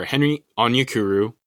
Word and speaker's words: Onyekuru: Onyekuru, is that not right? – You didn't say it that way the first time. – Fine, whatever. Henry Onyekuru. – There you go.